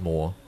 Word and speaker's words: Mooar: My last name's spelt M-O-O-A-R. – Yes, but it's just pronounced Moore.